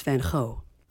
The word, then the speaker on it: Van Gogh: Yeah, because I think I say Van Gogh, but I think it's actually, isn't it Van Gogh or something? I think we're all saying it wrong. – Well, in Holland, it's Van Gogh.